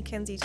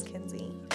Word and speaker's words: Kenzi: Most people call me Kenzi Todd. Like, no one ever just says Kenzi.